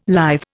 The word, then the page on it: lithe: Indian